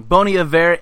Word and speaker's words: Bon Iver: And my third song this week is called Take Me to the Light by Francis and the Lights, Bon... No, I almost said it wrong. Bon Iver and Kanye West.